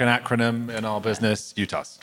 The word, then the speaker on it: UTAS: Yeah, and to be clear, do you actually pronounce it UTAS, or did I just make that up? – No, we do.